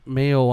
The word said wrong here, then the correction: Mayhew: on the radio. – Mayhew. – That's right. – Mayhew. – Not Mayo.